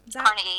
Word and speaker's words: Carnegie: So it's How to Win Friends and Influence People by Dale Carnegie. – Carnegie, yeah.